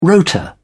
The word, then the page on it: rota: British English